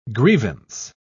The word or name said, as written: grievance